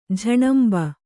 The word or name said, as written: ಝಣಂಬ